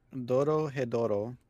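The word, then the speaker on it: Dorohedoro: The other five are Beastars, Jujutsu Kaisen, The Great Pretender, I think it's Dokoro Doro. – Doro Hedoro. – Doro Hedoro, thank you.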